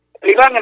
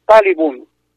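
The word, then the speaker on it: Taliban: Talibán en realidad es una mala pronunciación, porque la pronunciación correcta es Talibun, significa estudiante.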